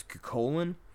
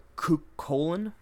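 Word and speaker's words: Cú Chulainn: One noted figure I want to mention real quick is Cuchulainn, or Cuchulainn, depending on how you want to pronounce it, if you want to have that hard C or not.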